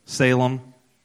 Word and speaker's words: Salim: And John also was baptizing in Aenon near Salim. Am I saying that right, Pastor? – Salem.